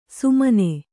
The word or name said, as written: ಸುಮನೆ